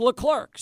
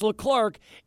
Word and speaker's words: Leclerc: And that's why I've got you on here because there is a uh, there's a pitcher for the Rangers whose last name is Leclerc, pronounced Leclerc. So here in baseball, it's Leclerc. In F1 racing, it's Leclerc.